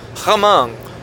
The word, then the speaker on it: Hmong: So not Korean, but maybe Hmong, or how do you say that with an H? Hmong or Hmong? Hmong.